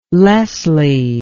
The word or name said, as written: leslie